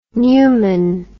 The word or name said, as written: pneumon